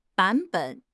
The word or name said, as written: 版本